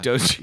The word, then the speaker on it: Doge: I think I read that Elon wants to do something with Dogecoin and Doge. Twitter. – Is that how you say it? – Yeah. – Doge.